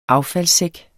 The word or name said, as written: affaldssæk